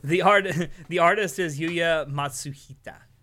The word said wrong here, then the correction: Matsushita: Or Matsushita, excuse me, Matsushita.